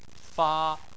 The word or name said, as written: fa1